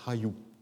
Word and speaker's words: Hayu: Mais la prononciation, c'est Hayu.